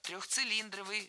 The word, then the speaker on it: трехцилиндровый: А вот здесь все верно. Трехцилиндровый, шестицилиндровый, восьмицилиндровый, сколько угодно цилиндровый. Всегда ударение ставим на слог «ли», как и в исходном прилагательном без первой части «цилиндровый».